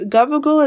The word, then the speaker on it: gabagool: But it says, gabagool is how Southern Italians pronounce capicola on the East Coast.